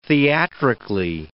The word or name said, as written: theatrically